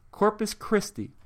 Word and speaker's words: Corpus Christi: So for Luke Harper to get some good cheers in Corpus Crispy, Texas, for heaven's sakes. I don't even know how to pronounce it. Corpus Crispy? Corpus Crispy. Corpus Christi. Corpus Christi, Texas, which is also the worst crowd in the United States.